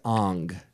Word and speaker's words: Aang: Like Ong. Aang is pronounced Ong.